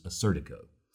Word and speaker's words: Assyrtiko: That's spelled Assyrtiko. Some people may pronounce it as Assyrtiko, but I've most often heard it in the United States pronounced as Assyrtiko.